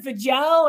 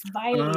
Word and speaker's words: Vejle: I mean, Viley is spelled, I think, V-E-J-L-E, and we were calling it Vigel or Vigelli, all kinds of stuff, until we got there and realized it kind of, we learned it rhymes with like Miley Cyrus. It's Viley.